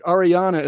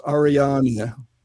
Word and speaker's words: Ariane: The story is that Ariana is the German pronunciation of A-R-I-A-N-E. They pronounce all the vowels, so it's Ariane. And of course, in French, it's Ariane.